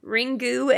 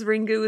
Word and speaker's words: Ringu: According to Chris, it should just be Ring. Because Ringu is just the way they pronounce it. – Yeah, because they don't have a like a glottal stop like in Japanese. So they need to use one of the sounds that they have that begins with a G.